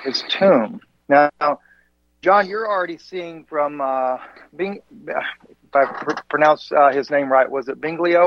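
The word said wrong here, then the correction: Biglino: Mauro, they don't pronounce the second syllable. Bilino is how they say it. – Bilino, okay, right.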